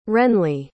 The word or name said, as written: wrenley